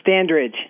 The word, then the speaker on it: Standridge: Standridge. – Standridge. Okay, I stand corrected. – Like standing on a ridge.